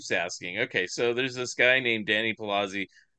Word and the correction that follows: Salazzi: Salazzi, I should say his name right. Danny Salazzi.